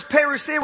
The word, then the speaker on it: parousia: The coming there, and don't get scared, is the word is parousia.